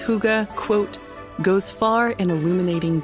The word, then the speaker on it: Hygge: It's spelled H-Y-G-G-E and yet pronounced Hygge because the word is Danish.